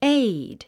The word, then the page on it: aide: éid; エイド